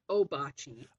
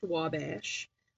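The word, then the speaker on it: Ouabache: Well, we pronounce it Obachi. – Obachi. – Obachi is how we pronounce it. There was a period of time where it was pronounced Wabash.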